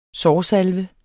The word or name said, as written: sårsalve